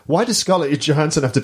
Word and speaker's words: Scarlett Johansson: They cast Scarlett Johansson is actually how you pronounce it.